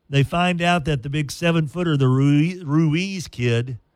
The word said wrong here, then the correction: Ruzic: Ruzic, well, crap, how would you say that, Esther? R-U-Z-I-C, Ruchik. Ruchik, yeah. The Croatian found out that afternoon that he was eligible to play.